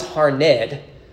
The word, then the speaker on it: Harned: No, it's Harned. It's two syllables in there.